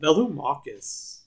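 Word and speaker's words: Velomachus: It's Vamachus Lorehold. I don't know if I pronounced that right. – Velumachus. – That's what I said.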